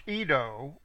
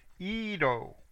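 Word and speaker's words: Iddo: Verse 21, of the half-tribe of Manasseh in Gilead, Edo, now that's pronounced Edo, okay, but it's Y-I-D-D-O. The Y is basically a silent, it has a little inflection in there. Edo, the son of Zechariahu of Benjamin, and Yaasiel, the son of Abner.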